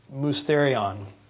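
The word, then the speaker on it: mysterion (μυστήριον): Well, the word mystery in the Bible, and you'll read it a number of times, especially in the New Testament, it represents the Greek word that's pronounced mysterion. So you can hear it. It sounds the same.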